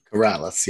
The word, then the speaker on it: Corrales: Is it Henry Corrales? – Corrales, yep.